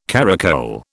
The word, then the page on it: caracole: British English